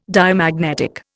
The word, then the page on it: Diamagnetic: Indian